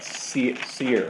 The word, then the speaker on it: Seir: until I come to my Lord at Seir.